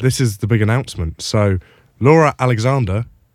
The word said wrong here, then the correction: Alexandra: who does... I've said her name wrong. – You're really good friends with her. Laura Alexandra.